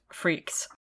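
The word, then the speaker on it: Freakx: Spelled freak with an extra X on the end, but it's pronounced Freaks.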